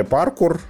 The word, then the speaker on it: паркур: То есть, например, они там вкачали паркур, да, то, что там теперь можно, вот это есть крюк. Когда Французское ты... слово, ударение на последний слог. – Паркур, да.